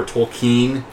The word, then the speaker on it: Tolkien: They do a thing about how to pronounce, is it Tolkien or Tolkien? It's Tolkien, and he corrects the headmaster.